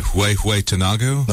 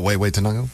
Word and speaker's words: Huehuetenango: Huehuetenango? – No, Huehuetenango. – Huehuetenango.